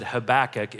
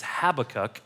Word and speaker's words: Habakkuk: I've taught this book of the Bible a couple of times, and I've been saying it incorrectly for about 10 years now. I always said Habakkuk. It's Habakkuk.